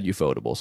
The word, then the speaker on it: ufotable: So like UFO table. – Yeah, I think it's actually you just pronounce it as a word. I think it's ufotable.